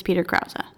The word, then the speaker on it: Krause: I think we were mispronouncing Peter Krause's name. And if we're going to talk shit about how somebody looks... I want to make sure we say his name right. So, pretty sure it's Peter Krause.